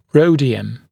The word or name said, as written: rhodium